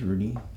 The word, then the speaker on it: Rudy: Now, the play on words is, so my name is Rudy. You spell it R-U-D-Y. But the way I spelled Rudy's is R-O-O-D-I-E-S.